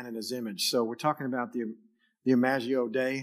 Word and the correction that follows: Imago Dei: If I said that right, is that how you say it, Alan? Imago Dei.